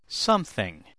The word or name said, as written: something